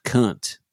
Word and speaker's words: Kant: Like I was honestly like I had a philosophy teacher in college that was just like, look, this guy's German. It's spelled K-A-N-T. It's pronounced cunt.